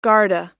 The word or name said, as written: garda